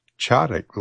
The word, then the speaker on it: Tzadik: This is on the Chadic label, I believe, is how it's pronounced, T-Z-A-D-I-K.